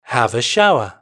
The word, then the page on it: have a shower: British English